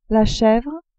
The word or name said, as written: chevre